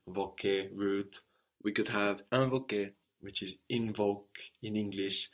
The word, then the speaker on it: invoquer: So we noticed there how the IN gave us un, invoquer. And this is because, again, the N on the end of the syllable is getting swallowed into the vowel, just like what we saw with confirmer.